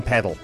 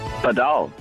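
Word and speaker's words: padel: Padal. The original name is Padal. – Mm-hmm. – Yeah. From a Cape sport, and then the normal way around here in the Cape Townian way and the South African way is paddle. – So if you're in South Africa, you play paddle. If you're anywhere else in the world, you play padal.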